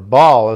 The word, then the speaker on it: Baal: And so this is this Hadad bloodline, with Hadad being another name for Baal, or Baal, as some people will pronounce it, in more of the Mesopotamian and the Canaanite pantheon.